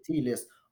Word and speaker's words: Chthe'ilist: I mean, a lot of people asked me in the past, like, how do you pronounce it? I'm like, Chthe’ilist.